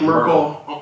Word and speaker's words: Merkle: No, like Merkle. Like Merkle on 34th Street.